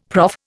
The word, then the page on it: prof: Indian